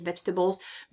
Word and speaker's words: vegetables: Divide your plate into four parts, and two of them, so one half of your plate, should be taken up by vegetables. Yeah, veggies, vegetables. Be careful of pronunciation here. It has nothing to do with tables. It's vegetables. Tables, not tables.